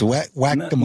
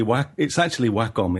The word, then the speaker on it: Wacom: It's Wacom, like whack-a-mole. – Wacom. – No, it's actually Wacom. It's a Japanese word.